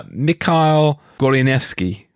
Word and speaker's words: Michal Goleniewski: Uh, Mikhail Gorinevsky. Did I get that right? That's absolutely right.